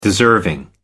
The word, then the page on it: deserving: American English